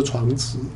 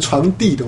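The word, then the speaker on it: 床笫: 那这个竹编的草席一般古代人是放在床上的，叫做床子。啊，你念成床地的话，人家会笑你，你在念什么人家听不懂。那你认不是啦，现在其实现代人是念床地大家听得懂啦，念床子大概大家是听不懂的，是不是？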